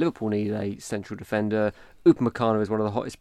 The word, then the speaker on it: Upamecano: I think Fabrizio Romano, you mentioned on one of last week's podcasts, that he suggested that Upamecano is going to stay at RB Leipzig. – Pronounce it right, please. Upamecano.